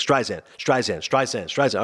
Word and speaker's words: Streisand: And he literally, the first thing he's telling the kid how to pronounce Barbara Streisand's name, he's like, Streisand.